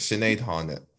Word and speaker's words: Sinead Harnett: So my Now song will be from Sinead Harrett. What the fuck was that? Harnett. – Harnett? I don't know it. See, I don't know anything. See, I saw it. I think I know how it's spelled, but you know when you say a word before you've ever said it? Sorry, Sinead. I like your music. Sinead Harnett.